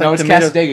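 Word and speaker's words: Cassadaga: Casadega, or is it Casadaga? Are you sure it's Casadega? I don't know. – It's awesome. It's Casadega because he says it in Four Winds.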